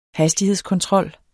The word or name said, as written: HASTIGHEDSKONTROL